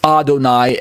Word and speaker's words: Adonai: but other lowercase O-R-D. This is because the Hebrew is not Y-H-V-H, Yodivave, Yahweh. Instead, it is the word Adonai, pronounced Adonai, as in comparison to Adoni from verse 1 above.